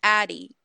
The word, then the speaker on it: Ady: So my name is pronounced Addy.